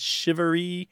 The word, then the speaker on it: charivari: It's chivalry. How would you spell that? – I've seen it C-H-A-R-I-V-A-R-I, mm-hmm. – but it's pronounced like S-H-I-V-A-R-E-E.